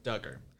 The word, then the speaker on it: Duggar: So how do you say his last name? Clarify that with me. – It's Josh Duggar. – Duggar. – Yes.